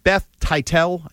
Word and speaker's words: Teitell: So I'm going to read you a quick little paragraph here from Beth Titel, is how I'm pronouncing it. T-E-I-T-E-L-L. – You got it.